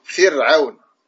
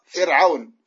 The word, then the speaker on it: فرعون: tu dis comme ça فر فر ع فر ع فرعون et non pas فرعون فرعون regarde la différence فرعون pourquoi on a tendance إلى تفخيم الراء on a tendance à prononcer را forte parce que العين elle est forte Et donc, tu vas prononcer Ra également forte. Alors que Ra ici, elle est muraqqaqa.